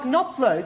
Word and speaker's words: Knopfler: When Mark Knopfler is Mark Knopfler. It's his name. He has a silent K.